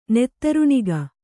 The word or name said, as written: ನೆತ್ತರುಣಿಗ